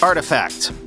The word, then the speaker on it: RTFKT: I have been informed that the Web3 company that I referred to yesterday in that Nike NFT story as RTFKT is actually pronounced artifact.